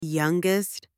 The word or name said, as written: youngest